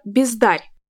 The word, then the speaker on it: бездарь: Как вы могли догадаться, в этом стихотворении неологизмом стало слово «бездарь», которое нам привычно слышать с ударением на первый слог. Все дело в том, что при образовании этого слова поэт действовал по аналогии со словами «бездарность» и «бездарный». Таким образом, первоначально ударение было на букву «а» и, соответственно, на второй слог.